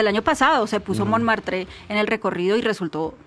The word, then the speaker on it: Montmartre: Pero el domingo pasamos por Montmartre, perdón mi pronunciación del francés, ya sé que así no se pronuncia. – Montmartre.